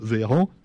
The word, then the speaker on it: Véran: Et là, c'est Olivier Véran, Véran pardon, pourquoi je me trompe toujours quand je prononce son nom, lui Oui, avant, il était ministre de la Santé.